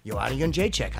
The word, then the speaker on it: Joanna Jędrzejczyk: Joanna Jędrzejczyk, I said it right.